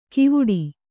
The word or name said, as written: ಕಿವುಡಿ